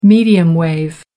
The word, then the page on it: medium wave: American English